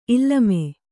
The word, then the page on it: ಇಲ್ಲಮೆ: illame